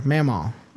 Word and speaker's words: Mamaw: Mamo, pronounced Mamo. There we go. Is what my nephew calls my mother. His grandmother. Mamaw. Okay, Mamaw. I was close.